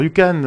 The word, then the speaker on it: YouCan: Alors si vous voulez chercher sur la toile, YouCan, ça s'écrit u k alors Yukan,